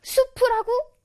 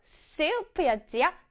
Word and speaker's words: soup: 수프라고? 아니, 아니에요. That's a no-no. 바로바로 soup였지요. Soup. Soup. S에서 힘을 줘서 말하기로 했지요.